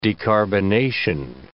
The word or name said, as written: decarbonation